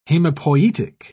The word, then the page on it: hemopoietic: British English